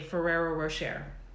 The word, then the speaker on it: Ferrero Rocher: Ferrero Rocher.